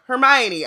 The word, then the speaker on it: Hermione: First off, we now know how to pronounce Hermione. Mm-hmm. I was like, again, when Ami came in and was like, Hermione, I was like, she's so lucky.